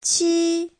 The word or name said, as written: qi1